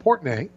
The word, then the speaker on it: Portnoy: You know, we were talking about Portnoy. Is that how you say his name? – Yeah, Dave.